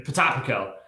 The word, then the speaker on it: Potapenko: And was it Potapenko? – Potapenko.